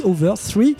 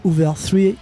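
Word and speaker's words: Hooveriii: Et quel groupe, c'était les Hoover 3, ça s'écrit avec 3 i à la fin, on dit Hoover 3.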